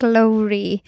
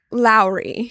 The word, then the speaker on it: Lowri: It is like Lowri, but you can say it however. Because I've heard people say Lowri.